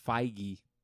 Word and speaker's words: Feige: You say Feig? – How do you say his name? – It's Feige.